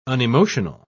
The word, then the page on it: unemotional: British English